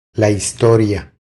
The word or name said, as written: La historia